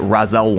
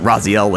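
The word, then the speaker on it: Raziel: Is it Ra'ziel or is it Ra'zel? Because I heard Michael Raparez call it Ra'zel once. – It's supposed to be pronounced that way. – Okay. – But everyone else says it the other way.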